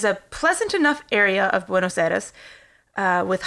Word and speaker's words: Buenos Aires: So he's born in Buenos Aires, Argentina.